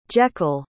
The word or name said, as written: Jekyll